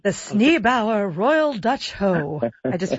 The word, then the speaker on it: Sneeboer: The Sneebauer, So it's spelled S-N-E-E-B-O-E-R, Sneebauer, the Royal Dutch Hoe.